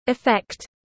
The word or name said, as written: effect